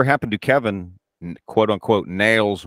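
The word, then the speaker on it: Nailz: Appeared in Paul Amperstein's American Wrestling Federation as Nails, pronounced Nails with a Z, alongside many WWF wrestlers from the 80s and 90s.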